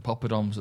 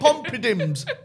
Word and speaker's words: poppadoms: It's four syllables. Behave yourself, you little fit twat. – It's three syllables. – I hate this. Is it? Pop-pa-dom. – Pop-a-dom. – Even less, even easier.